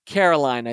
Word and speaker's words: Caroline: Pronounced the way Neil Diamond says it. Pretty sure that's sweet Caroline.